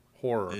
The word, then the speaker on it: horror: Syllables or one syllable? Horror. – It is two. Horror.